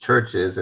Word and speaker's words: CHVRCHES: first of all, if you're searching for this song, is they spell their name C-H-V-R-C-H-E-S, even though it's pronounced churches, and that's why they did it, so that they would come up better in search, right?